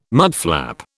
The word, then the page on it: mudflap: British English